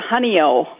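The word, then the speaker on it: Honeoye: Well, most of them are, it's a honey-o. Most of the ones that honey-eye. – I have are honey-o. – Honey-eye, honey-o, however you want to pronounce it.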